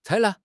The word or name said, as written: 采纳